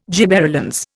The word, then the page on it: gibberellins: Indian